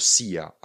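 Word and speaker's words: silla: Ale je to sia a jako jo se vyslovují co? Dvě l. Takže píšeme s i l l a a je to sia.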